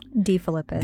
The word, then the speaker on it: DeFilippis: De Philippus.